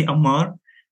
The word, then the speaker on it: Ammar: First of all, just my name is Ammar Al-Ashqar. Just you can say Ammar. It's a little bit just, you know, like, hard to pronounce like Arabic letters, and I prefer that.